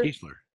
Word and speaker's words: Keesler: Keesler, yeah.